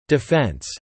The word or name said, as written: defence